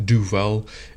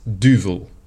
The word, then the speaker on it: Duvel: Some people in America pronounce it Duvel. It's Duvel.